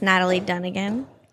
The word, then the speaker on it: Natalie Dunigan: We have Natalie, Miss Natalie Dunigan.